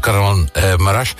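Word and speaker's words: Kahramanmaraş: Was het vergelijkbaar, deze situatie die je gezien hebt in bijvoorbeeld Karaman Maraş? Spreek je goed uit, Yeshim, eigenlijk? – Ja.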